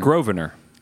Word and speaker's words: Grosvenor: Grosvenor. – It's G-R-O-S. – I know, but the S is silent.